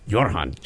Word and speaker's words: Goran: I was hoping you'd bring back Goran. Goran. How do you say it? – Jorhan. I can't roll my R.